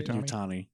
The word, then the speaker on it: Yutani: Did Wayland, what is it, Yutari, how do you say Yutani.